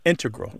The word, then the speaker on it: integral: Just to clarify here, both correct, but the mathematicians mm-hmm. and math-using scientists are more likely to put, say, integral with the stress on the first syllable.